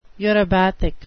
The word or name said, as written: eurybathic